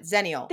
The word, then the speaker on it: Xennial: Zennial.